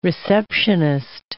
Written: receptionist

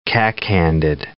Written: cack-handed

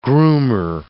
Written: groomer

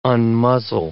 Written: unmuzzle

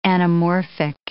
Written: anamorphic

